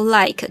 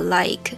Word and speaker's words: like: 比如说 I 好了，它如果是 I 跟 E 中间有，比如说 like 就那个喜欢的那个 like，它。它那个 e 后面的 e 就不发音，但是 i 就要发长音的 i 啊之类这种发音规则，就让人对。